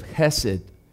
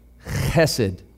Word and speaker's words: chesed: And we learned about this Hebrew concept of chesed. You almost have to say it. Chesed. Chesed. Can you say it?